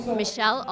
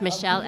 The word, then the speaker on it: Michelle: Michelle Almond. It's Michelle, M-I-C-H-E-L-L-E, and it's Almond like the nut, A-L-M-O-N-D.